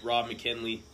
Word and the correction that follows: McElhenney: McElhenney. – I think you're right.